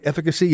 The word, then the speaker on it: efficacy: And Aaron Boone said that all 8 received the Johnson & Johnson one-shot deal, which has a much lower efficacy rate. Did I pronounce that right? Efficacy? Yeah.